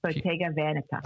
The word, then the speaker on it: Bottega Veneta: It's Bottega Veneta. Is that right? – Bottega Veneta.